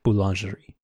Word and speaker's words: boulangerie: I went to Google and it has the little speaker button to tell you how you're pronouncing it. And I pronounced it correct. Boulangerie.